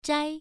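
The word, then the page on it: zhai: tone 1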